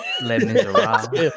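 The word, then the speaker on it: Les Misérables: Les Miserables. I don't know. I can't pronounce it. – Les Miserables. – Les Miserables.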